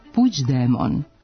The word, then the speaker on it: Puigdemont: Navode nas na zaključak da se u španskom krajnje mont čuje zajedno sa završnim t, te se verovatno po analogiji s tim izgovorom pojavio oblik Puigdemont, koji katalonci izgovaraju Puigdemont.